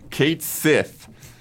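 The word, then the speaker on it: Cait Sith: Kate Sith,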